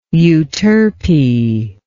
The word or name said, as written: euterpean